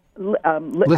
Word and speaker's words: Lissa: Lissa. I think she pronounces it Lissa. – Lissa. Okay, Lissa Evans.